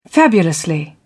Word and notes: fabulously: British English

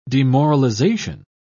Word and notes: demoralisation: British English

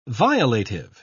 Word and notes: violative: British English

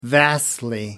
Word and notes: vastly: American English

